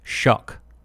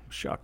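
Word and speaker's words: Shough: I looked it up, and it's pronounced Shuck, like Shuck. Rhymes with duck. – Oh. Shuck.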